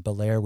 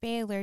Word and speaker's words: Bel Air: The napalm in the morning scene was filmed on Belair, which you've correctly indicated is pronounced Bel Air, and I appreciate that. – Well, I'm wondering too, because you were calling it Baylor during the talk, but would other Americans in the 70s that were they calling it Baylor? – I doubt it.